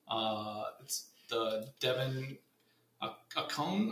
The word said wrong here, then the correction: Achane: or Coney. – A chain. – A chain? Yeah, I'm sorry. It's a chain. – Devin Achane? – Yeah, yeah, the Texas A&M speedster.